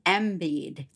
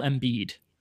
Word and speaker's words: Embiid: And he's from the 76ers, and his name is Joel Embiid. Did I say that right? – Not quite. Joel Embiid. – Joel Embiid. – Yes.